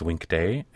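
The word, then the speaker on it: Winkte: Well, it's actually pronounced Winktee, and it's actually short for a longer word, Winktee.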